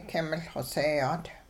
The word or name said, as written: king-miL xoseeyaat